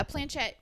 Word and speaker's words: planchette: And what is a planchette? I don't know if I'm saying that wrong or not. – Yeah, a planchette.